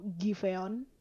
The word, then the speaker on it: Giveon: Do you know Giveon? I don't know, am I pronounce it right? – Yes, I don't know him that good.